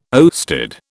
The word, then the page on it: oersted: British English